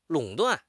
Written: 垄断

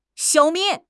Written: 消灭

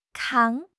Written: 扛

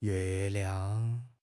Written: yue4 liang5

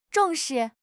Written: zhong4 shi4

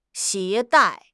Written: 携带

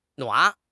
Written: nuan3

